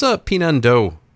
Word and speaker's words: Pinan Do: What's Pinando Karate? And did I pronounce that right? – You did, actually.